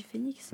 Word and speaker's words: Phoenix: Ça s'écrit Phoenix. – Ouais. – Ça se dit Phoenix. – Ouais, Phoenix.